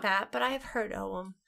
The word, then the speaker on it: Ogham: It's Oum. – But the GH. – I know, but it's always been pronounced Oum to me. Wait, okay. – Yeah. – Break our tie. Is it Oum? – Or Ogham. Or Ogham. – Because the GH is the hard English G.